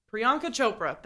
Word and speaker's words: Priyanka Chopra: Nick Jonas, uh, formerly of the Jonas Brothers and current uh, pop star, just got married to I'm gonna butcher her name. Priyanka Chopra. – Thank. Yep, Priyanka Chopra.